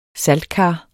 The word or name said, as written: saltkar